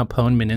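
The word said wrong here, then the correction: Ponemon: Institute Research. – Ponymon, we've established it's that.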